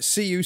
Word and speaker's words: Sioux: Investigators said Lee Bowman, 53, of, I think I'm saying this right, CU City, S-I-O-U-X. – Like Sioux Falls.